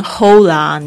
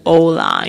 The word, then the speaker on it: hola: humor 这个我现在在念 humor 的时候，我就有一个感觉，因为我现在在学西班牙语，西班牙语这个 H 开头的字通常是不发音的，比如说他们这个打招呼这个 HOLA 你不能念 HOLA，你要念 OLA，因为在西班牙语是没有 H 这个声音的， OK，他没有 H，但是他们没有 H，就是没有呵呵呵这个声音，而是那种有一点好像要吐痰那个呵的声音。